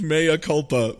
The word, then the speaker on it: mea culpa: Instead of mea culpa, Mikupa. I really like it. Okay, Mikupa. I'm sorry. Mikupa. – That's how it's pronounced, right? – No, it's mea culpa. But I will accept Mikupa.